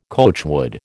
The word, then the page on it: coachwood: American English